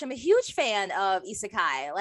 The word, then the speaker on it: Isekai: So our topic for today is the lovely, lovely world of Isekai.